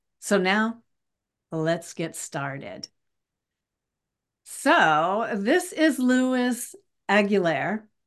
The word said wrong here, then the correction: Luis Aguilar: Luis Aguilar.